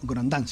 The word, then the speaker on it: Grondin: Traje uno chiquito que es un gran filósofo contemporáneo canadiense, Jean Grondin. Grondin sería, ¿no? En francés, vos que sabes más que yo. ¿Sí? Grondin, pero se escribe Grondin para los que no se escucha.